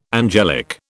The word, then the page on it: angelic: British English